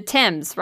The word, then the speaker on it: Thames: The Thames, right? – Yeah.